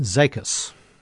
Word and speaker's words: Zekas: Zekas. – Zekas. – That's how she pronounced it. – Zekas. So the S is silent.